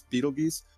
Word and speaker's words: Betelgeuse: We've got basically what happened was when a couple months back when people were saying, oh, Betelgeuse is dimming. And I know there's going to be some pedantic people out there going, actually, it's Betelgeuse. No, actually, it's both pronunciations are correct.